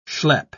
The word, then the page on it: schlepp: British English